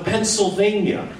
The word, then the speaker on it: Pennsylvania: You leave the L out of Pennsylvania. – Marty, please pronounce the L in Pennsylvania. – It certainly would be nice if you pronounce the state you live in properly. Pennsylvania has an L in the spelling.